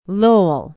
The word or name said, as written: lowell